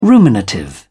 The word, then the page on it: ruminative: British English